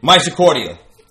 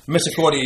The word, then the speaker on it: Misericordia: A nursing college, Misericordia University. – Misericordia. – Whatever. – Well, that's how you say it. Your reading is not good, man. Misericordia University.